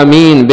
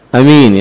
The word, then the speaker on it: آمين: وفي آمين لغات، قال العلماء أفصحها آمين بالمد وتخفيف الميم آمين، والثانية بالقصر، وهاتان مشهورتان. وكيف تكون بالقصر؟ كيف تنطقها بالقصر أمين يعني بدون مد؟